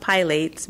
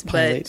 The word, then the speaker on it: Pilates: It's not pronounced Pilates. Pilates.